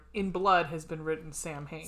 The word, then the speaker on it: Samhain: Samhain, a Gaelic word pronounced Samhain. Samhain. So, yeah, Samhain.